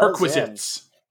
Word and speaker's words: perquisite: Like requisite, but perquisite. Perquisite. No, not prerequisite.